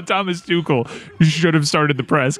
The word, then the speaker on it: Tuchel: I have not heard Tuchel. – Tuchel. – There you go.